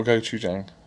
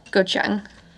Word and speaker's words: Gochang: As Gochang or Gochujang? – Gochang. – As in the source? – No. So, this is spelled G-O-C-H-A-N-G.